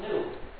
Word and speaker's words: GNU: The reason is, according to the dictionary, it's pronounced GNU. And this silent G just strikes people as funny. But when it's the name of our system, please ignore the dictionary and pronounce a hard G. Pronounce it GNU.